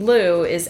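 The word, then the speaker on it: Lue: In a few of the publishings we read, the name Lu is actually pronounced Lui.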